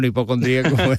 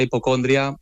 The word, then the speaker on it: hipocondría: A ver, para mí oficialmente es hipocondría, con acento en la I. – Exacto. – Pero me he encontrado mucha gente que lo llama hipocondria y bueno, nos entendemos.